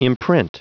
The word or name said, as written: imprint